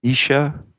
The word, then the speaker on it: Naoise: Spelled N-A-O-I-S-E. And I guess it's pronounced Isha.